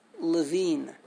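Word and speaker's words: Levin: They spell it without an E. L-E-V-I-N, but they pronounce it Levine.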